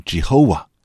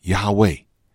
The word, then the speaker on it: YHWH: YHWH 这个是不发出声音的名字，那常常呢就被读成耶和华 Jehovah，或者是亚威亚威，或者是呢与希伯来文的动词是联系在一起，就是出埃及记三章十四节所说的神是自有永有的神，原文呢就是我是。